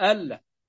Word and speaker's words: أن لا: ألا، لكن الصحيح أنها تنطق أن لا، آسف، تكتب أن لا وتنطق ألا.